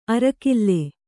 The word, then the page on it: ಅರ್ಕಲ್: arkal